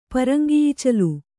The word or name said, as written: ಪರಂಗಿಈಚಲು